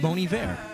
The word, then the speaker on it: Bon Iver: It's pronounced Bon Iver. – Oh, it's spelled B-O-N, and the second name is I-V-E-R.